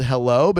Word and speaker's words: Heilo: It is called Hello, but it is spelled H-E-I-L-O.